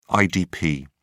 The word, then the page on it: idp: British English